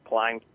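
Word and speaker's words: Klimb: And so it's K-L-I-M-B, climb spelled with a K.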